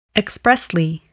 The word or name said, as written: expressly